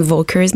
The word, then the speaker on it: Völkers: Euh, puisque c'est allemand, c'est Engel. La majorité des gens disent Engel Volkers, mais il faut prononcer le V comme un F.